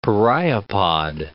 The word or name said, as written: pereiopod